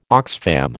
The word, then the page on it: Oxfam: American English